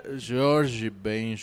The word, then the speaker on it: Jorge Ben: Bueno, bien pronunciado se diría George Ben George. – Ben George. Ajá.